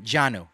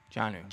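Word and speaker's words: Jonnu: It's not Jonah. Oh, man. Janu. – Janu.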